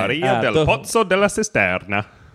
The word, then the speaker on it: Maria del Pozzo della Cisterna: How would you pronounce the name Maria del Pozzo della Cisterna? – With a slightly more racist accent. – Maria del Pozzo della Cisterna. – Yeah, well, actually, that's perfect.